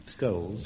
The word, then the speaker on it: Scholes: Percy Shoals. – Scholes. – Describes. – Scholes, is it? Scholes, yeah.